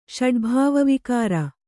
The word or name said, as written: ಷಡ್ಭಾವವಿಕಾರ